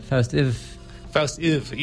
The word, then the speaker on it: Faust IV: And we're going to end off with another track from this Faust 4 album, or as I call it, Faust Iv. – Yeah, I believe that's the pronunciation they prefer.